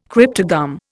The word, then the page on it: cryptogam: Indian